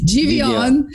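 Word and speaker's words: Givionne: Jordan Jr. I learned the proper pronunciation is, oh, you're going to have to say it again. Giovanni? – Giovanni. It's government.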